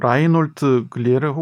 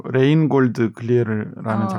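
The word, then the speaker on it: Reinhold Glière: 음, 라인홀트 글리에르 혹은 러시아식 발음으로 레인골드 글리에르라는 어, 작곡가인데요.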